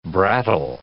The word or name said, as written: brattle